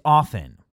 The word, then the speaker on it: often: Is it often or often? Nope. It is often. We are saying it wrong.